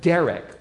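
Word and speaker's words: derech (דרך): The word way here is the Hebrew word derech, like the boy's name, derech, and it's spelled precisely the same way as well, derech.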